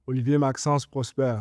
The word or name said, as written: Olivier-Maxence Prosper